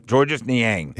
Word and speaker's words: Georges Niang: Georges Niang? – Yeah, Georges Niang.